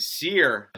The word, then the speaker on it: Cyr: And Cyr, C-Y-R, how you spell it, but I think you pronounce it Cyr, new Smashing Pumpkins, and sounds good, 33 years later, that's crazy.